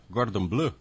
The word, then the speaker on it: Cordon Bleu: Será que eu vou escorregar na pronúncia? Cordon Bleu? É isso, André? Bom dia. – Bom dia. É exatamente isso.